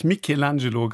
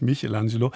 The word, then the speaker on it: Michelangelo: Meine Damen und Herren, Sie brauchen gar nicht anzurufen, um mich darauf hinzuweisen, dass ich Michelangelo gerade verkehrt ausgesprochen habe, nämlich Michelangelo. Aber ich habe eine Italienerin neben mir sitzen und die hat es natürlich gleich gesagt.